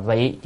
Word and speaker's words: vị: Chữ này đọc là vị chứ không phải đầu vi.